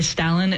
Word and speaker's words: Stalin: Common to use the A that's just more of a typical A rather than R sound. So the Stalin is the way that I grew up pronouncing it.